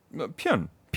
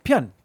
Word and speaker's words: Pyun: How do you say his name? Pyun? – Pyun. – Pyun.